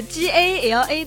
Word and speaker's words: GALA: 所以接下来就让我们先一起来听一下这首来自 G A L A 的《追梦赤子心》，但是我不知道这个歌手他的名字怎么读哎。 – 这个叫做 Gala，是一个组合啦。